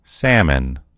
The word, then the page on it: salmon: American English